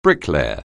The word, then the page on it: bricklayer: anglais